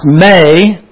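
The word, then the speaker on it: may (μή): It's actually not pronounced me, it's pronounced may. I should let Scott explain this. This is an eta. It's an E, but it sounds like an A.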